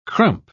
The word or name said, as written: crump